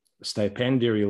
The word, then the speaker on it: stipendiary: uh, a uh, stipendiary lecturer.